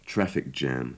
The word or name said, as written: traffic jam